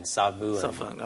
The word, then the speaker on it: Savoon: So you can write it down, S-A-V-O-O-N, Savuon.